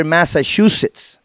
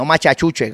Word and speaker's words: Massachusetts: Estuve por allá un año y cursé el noveno grado allá en la escuela, en la South High School de Worcester, Massachusetts. ¿Ah? Lo digo bien, Massachusetts, ¿ok? No machachuche, como dicen allá los íbaros de mi pueblo.